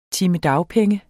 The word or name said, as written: dagpenge